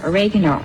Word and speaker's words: oregano: And this is an Italian herb, oregano, or as some people pronounce it, oregano. – Oregano. – The way she pronounces herb with a hard H, and then the pronunciation of oregano is It's a British thing. In Britain, they say oregano.